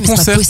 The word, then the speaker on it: conserve: C'est quand même fou parce que les boîtes de conserve, ça se garde tellement longtemps. – C'est marrant, tu dis conserve, toi, les boîtes de conserve. – Conserve. – Oui, il faut articuler la dernière syllabe.